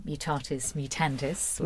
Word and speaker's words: Mutatis Mutandis: tell us a little bit about the new album, Mutatis Mutandis, which I hope I'm saying right. – No, you're saying it right.